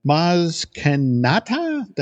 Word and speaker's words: Maz Kanata: For example, Joe was unable to unearth about Maz Kanata, that I'm pronouncing yes. – Name? – Yes.